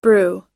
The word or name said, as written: brew